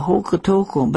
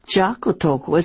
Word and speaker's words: Jocotoco: Isn't that a J? – That's right. Of course, in English, we pronounce that letter J, a J sound, but in Spanish, they don't pronounce it. They pronounce it like a Y. So that's why we hear it called Hocotoco instead of Jocoto or something with a J.